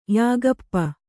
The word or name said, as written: ಯಾಗಪ್ಪ